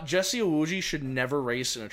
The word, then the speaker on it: Iwuji: And then Jesse, how do you pronounce his last name? – Jesse Iwuji. – Iwuji. – Iwuji, yeah.